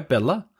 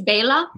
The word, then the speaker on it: Bela: Am I saying that right, by the way, Bela? – It's Bela. – Bela. – Yeah, Bela. But I often say Bela. I slip into Bela because, you know, you're used to it, but I'm getting better at it, but it's okay.